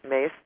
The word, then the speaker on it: Myss: It's spelled M-Y-S-S, but it's said Mace.